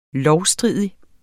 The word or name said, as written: lovstridig